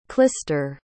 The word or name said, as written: klister